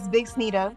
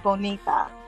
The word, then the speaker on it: Bxnita: It's not Bronxnita or anything like that. It's Bxnita. My business name is Moy Bxnita, but you pronounce it as Bonita. That's why I always tell people I'm Bonita. But I can't, you know, use B-O-N-I-T-A because it's already a word.